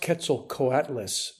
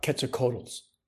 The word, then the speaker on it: Quetzalcoatlus: You mean these are the are these the Quetzalcoatls? – It's pronounced Quetzalcoatlus, not Quetzalcoatls.